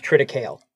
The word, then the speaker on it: triticale: As someone with an ag degree, it is pronounced triticale.